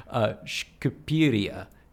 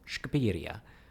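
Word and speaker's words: Shqipëria: It's spelled S-H-Q-I-P-E with the two dots on top, R-I-A, Shkupiria, which it's an epic pain in the ass to pronounce if you're not, you know, if you're an English speaker and you haven't heard it first.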